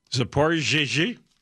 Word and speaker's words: Zaporizhzhia: Zaporizhzhia.